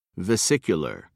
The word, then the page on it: vesicular: British English